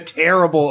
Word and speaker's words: Tearable: It is called The Tearable RPG. That's spelled T-E-A-R-A-B-L-E. Terrible as in to rip something apart.